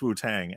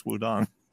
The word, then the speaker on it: Wu-Tang: My partner's mother, who lived in China for a while, refers to them only as the Wudong clan, because that's the correct pronunciation. And there's no amount of times you can correct her and be like, it's Wu-Tang. And she'll go, it's Wudong.